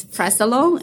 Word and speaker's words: Fresolone: It's Fresolone, and it's spelled as F-R-E. S-O-L-O-N-E.